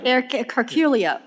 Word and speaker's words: Heraclio: I would say Heraclio. Okay. I apologize. Je parle français un petit peu. – So, the Spanish tend to remove those H's.